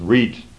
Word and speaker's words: riht: The one is R-I-H-T, perhaps pronounced reet, from the old Anglo-Saxon, which means right, or means true or straight.